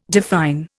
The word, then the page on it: define: Indian